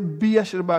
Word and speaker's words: Beersheba: Now, Bathsheba or Beersheba or whatever it is, when I say Beersheba, I can actually pronounce it and spell it easy because I said Beer and Sheba is easy.